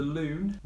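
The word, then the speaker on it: lune: The loon rhymes with moon.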